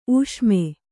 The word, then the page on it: ಊಷ್ಮ: ūṣma